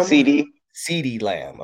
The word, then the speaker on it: CeeDee Lamb: Then we got Sidney Lamb. – CeeDee Lamb.